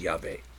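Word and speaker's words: Yahweh (יהוה): Yud-Heh-Vav-Heh, that I pronounce Yahweh.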